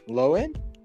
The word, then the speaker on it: Lohan: Lohan? – Yeah. Lohan? – The H is silent.